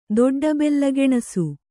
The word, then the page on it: ದೊಡ್ಡಬೆಲ್ಲಗೆಣಸು: kannada